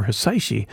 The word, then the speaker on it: Hisaishi: The kanji for Hisaishi can be read as the word which sounds suspiciously like Quincy.